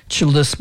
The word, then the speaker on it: çıltıs: Çıltıs, by the way, is the same word as yıldız, which means star in Turkish. They pronounce Y's as ç, çıltıs.